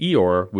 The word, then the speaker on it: Eeyore: Eeyore is a name invented by a kid with a non-rhotic, literally the non-R-like British accent, so that Eeyore would be pronounced very much like a donkey's sound, and an American version would be hee-haw.